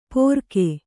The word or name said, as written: ಪೊರಕೆ